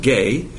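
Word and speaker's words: gay (γῆ): That word is G-E, which is pronounced gay, the landmass of the earth.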